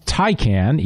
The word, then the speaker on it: Taycan: It's pronounced Taycan, even though it's spelled T-A-Y-C-A-N.